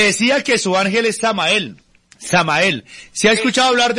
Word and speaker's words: Samael: Samael. – Sí. – Samael, se escribe uh-huh. S-A-M-A-E-L.